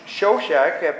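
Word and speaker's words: Shoshenq: But this guy fled there to Egypt to Shishak, king of Egypt. Now I looked this guy up. His name is pronounced slightly different in the Egypt, Shoshak.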